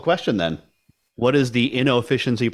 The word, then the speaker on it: Innoficiency: I hit the O on it. So I've invented a word, inefficiency. – Inefficiency. – I've combined the words innovation and efficiency into one word.